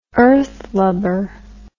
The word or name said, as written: earthlubber